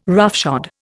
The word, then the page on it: roughshod: Indian